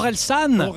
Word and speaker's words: Orelsan: I think I'm pronouncing it wrong. I think it's Horizon. – Ah, Orelsan.